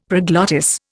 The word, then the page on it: proglottis: Indian